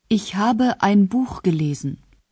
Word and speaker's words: gelesen: Lesen, gelesen.